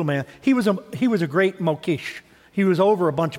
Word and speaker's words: Mokhes: Then there's a group called the Mokesh. M-O-K-H-E-S. It's pronounced Mokesh.